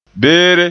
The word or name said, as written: beere